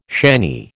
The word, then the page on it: shanny: American English